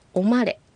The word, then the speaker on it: オマレ: はい、次、おまれ、これは何々を何々に入れるという意味の単語です。 – おまれ。 – はい、まあのところにアクセントがつくように発音するといいと思います。